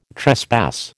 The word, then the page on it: trespass: American English